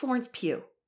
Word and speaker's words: Florence Pugh: How do you say Florence Pugh? – Florence Pugh.